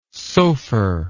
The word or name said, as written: sopher